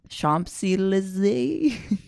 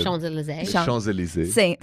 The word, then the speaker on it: Champs-Élysées: Champs-Élysées. – Well said. – Champs-Élysées.